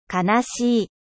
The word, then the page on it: triste: French